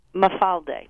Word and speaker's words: Mafalde: Can you say the pronunciation of this wavy pasta for me? – Mafalde. – Mafalde. Okay. – Mafalde. – Beautiful.